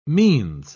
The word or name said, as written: means